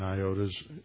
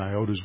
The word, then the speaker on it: iota: iota's, we always traditionally mispronounce it like a long I, it doesn't make an iota's worth of difference.